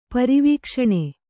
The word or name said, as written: ಪರಿವೀಕ್ಷಣೆ